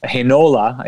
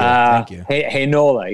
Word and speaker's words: Heinola: that's what we're seeing from Ville Heinola, is how he pronounces it. – Thank you. – Heinola, yes. He's been very, very impressive.